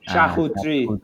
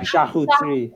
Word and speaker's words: charcuterie: It's charcuterie. – Oh, yeah, charcuterie. That's how you pronounce it.